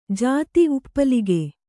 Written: ಜತಿ

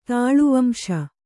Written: ತಾಳುವಂಶ

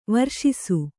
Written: ವರ್ಷಿಸು

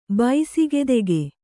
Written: ಬೈಸಿಗೆದೆಗೆ

